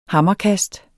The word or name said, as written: hammerkast